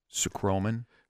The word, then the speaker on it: Sucroman: They've got a product that I'll probably mispronounce as well, Sucroman, accent on the first syllable.